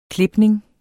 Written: klipning

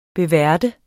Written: beværte

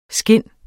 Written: SKINDENES

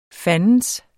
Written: fandens

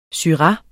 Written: SYRAH

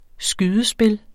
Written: skydespil

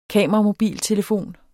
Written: kameramobiltelefon

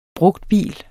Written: brugtbil

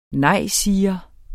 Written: nejsiger

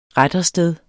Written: rettersted

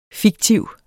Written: fiktiv

